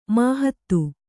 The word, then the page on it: ಮಾಹತ್ತು: māhattu